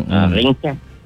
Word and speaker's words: ringkat: Ejaan dia R-E-N-G-K-A-T. Atau dia ada satu lagi, dia tak sebut ring. Mm-mm. Dia sebut ring. Ringkat. Ejaan dia R-I-N-G-K-A-T.